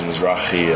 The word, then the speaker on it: Mizrachi: Some people think it's Italian, say Mizrachi. Some Americans say Mizrachi. Everyone has Rocky Balboa kind of. – Yeah. – And the real pronunciation is Mizrachi and like an Israeli pronunciation.